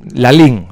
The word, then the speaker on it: Lalín: Pues cuando quieras situarte en el medio de Galicia, en el centro geográfico, tienes que ir a Lalín. Pueblo de unos 10.000 personas. Lalín. Con acento en la última ahí.